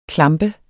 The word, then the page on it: klampe: [ ˈklɑmbə ]